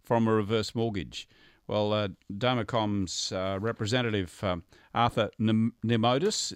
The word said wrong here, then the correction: Namides: Very close. It's Namides. – Namides.